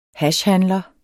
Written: hashhandler

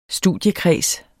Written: studiekreds

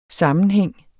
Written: sammenhæng

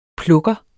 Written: plukker

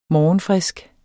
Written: morgenfrisk

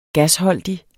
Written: gasholdig

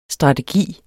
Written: strategi

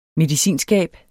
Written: medicinskab

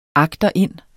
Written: AGTERIND

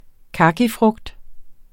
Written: kakifrugt

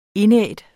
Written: indædt

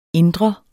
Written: indre

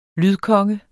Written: lydkonge

